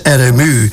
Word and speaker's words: Arnemuiden: Een andere plaats in Zeeland is Arnemuiden, en op zijn Zeeuws uitgesproken als RMU.